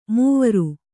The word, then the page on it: ಮೌವರಿ: kannada